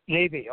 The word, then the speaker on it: Levy: Levy.